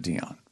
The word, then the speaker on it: Diane: What It's spelled Diane, but. – Right. – She pronounced it Dion.